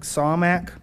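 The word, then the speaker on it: Samak (סמך): This word in Hebrew is the H5564, C-A-M-A-K, pronounced more like Samak, and it means to prop or to lean upon or take hold of.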